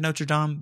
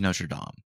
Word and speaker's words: Notre Dame: And I said Notre Dame because that's the proper way to say it. To all of you who are saying Notre Dame, you are wrong. It is Notre Dame. Be French about it, dude. Dude, be freaking French about it, man.